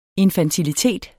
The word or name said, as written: INFANTILITET